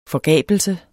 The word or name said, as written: forgabelse